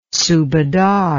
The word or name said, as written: subadar